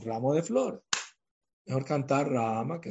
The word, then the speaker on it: Rama: La pronunciación es rama. No es la rama de un árbol. La primera A es más larga. – Rama. Y la segunda más chiquita.